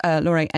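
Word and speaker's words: Lore: And it's called, Laurie, and it's L-O-R-E, in case you're looking for it, because it's spelt in the German way.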